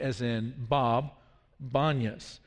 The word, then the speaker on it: Banias: Now, the reason is that it's sometimes called Banias is because the letter P is not natural to the Arabic alphabet and language, and so all of the P's are pronounced as B. So if your granddad is Papa, in Arabic he would be Bapa.